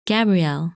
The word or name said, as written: Gabrielle